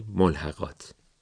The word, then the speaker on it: ملحقات: بعضن میبینیم که کم هم نمیبینیم، این دوتا واژه رو با تشدید تلفظ میکنن و در نوشته هم میبینیم تشدید رو میذارن. کجا؟ مستندات روی دال، ملحقات روی قاف. هیچ کدوم از این کلمه ها که هر دو عربی هستن تشدید ندارن.